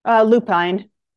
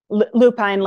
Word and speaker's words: Lupine: Lupine. Lupine, like the flower.